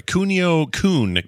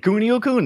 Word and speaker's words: Kunio-kun: We're going to do uh, River City Ransom and the uh, Cuino. I never say it right. Kunio Kun games. You got it. Kunio Kun.